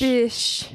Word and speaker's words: Biche: Like B-I-C-H-E. Like Nouveau Bitch. – Bish. – Except nice. The bitch is spelled like mm-hmm.